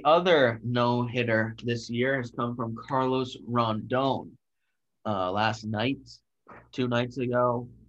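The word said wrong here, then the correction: Rodón: What do you – did I say his name wrong? – You said Rondon. I was trying to find the end in there. – Rodon.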